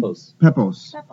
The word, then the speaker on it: Peppos: David Peppos? Is that how you say his name? – Peppos.